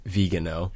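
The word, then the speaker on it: Viganò: We were saying it wrong in our last episode, so it's it's Vigano.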